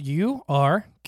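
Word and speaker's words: Urquhart: But Ewkirk Castle, Ewkirk. It's Ewkirk. – Okay. – How would you say that? – Ewkirk.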